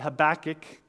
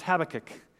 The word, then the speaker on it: Habakkuk: Now, I pronounce it Habakkuk because I'm from America. I've heard it pronounced Habakkuk if you're from Britain or other places. So you can call it Habakkuk or Habakkuk.